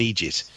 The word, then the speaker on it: eejit: Egypt, by the way, is spelled E-E-J-I-T for you Americans. It's an Irish way of saying idiot. It's known as an Egypt, right? And that's how it's said.